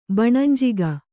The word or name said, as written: ಬಣಂಜಿಗ